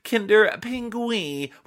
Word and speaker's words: Kinder Pingui: Alice Rossi writes, Dear Donalds, I feel it's my duty to inform you that Kinder Pingui is actually pronounced Kinder Pingui with an accent on the last I.